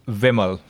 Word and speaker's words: Vimal: There's a slight change in pronunciation. – Okay. – It's Vimal with a short E.